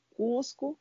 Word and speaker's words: Coozco: Então, o nosso Instagram é o arroba Cusco, Cusco C com dois Os, Z, C, O, se pronuncia Cusco.